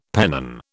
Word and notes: pennon: British English

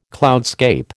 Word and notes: cloudscape: American English

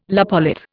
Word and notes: lopolith: Indian

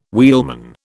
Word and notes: wheelman: British English

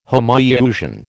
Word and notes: Homoiousian: American English

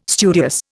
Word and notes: studious: Indian

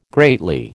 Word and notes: greatly: American English

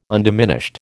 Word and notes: undiminished: American English

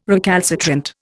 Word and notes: recalcitrant: Indian

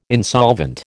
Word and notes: insolvent: American English

